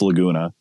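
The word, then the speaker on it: Lagoona: So I went with Laguna Guide Service. And that's spelled Laguna, L-A-G, two O's, L-A-G-O-O-N-A.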